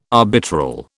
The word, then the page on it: arbitral: British English